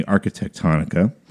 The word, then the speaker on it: Arquitectonica: But the design of the hotels, that was done by a company called Arquitectonica. – Jeez. – Another word that rhymes with harmonica. – Yeah, say that five times Arquitectonica.